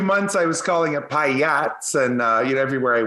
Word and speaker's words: pyATS: but it's pyATS, if you could just maybe get the name right.